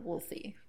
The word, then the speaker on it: Wolsey: W-O-L-S-E-Y, Wolsey.